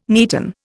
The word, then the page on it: neaten: Indian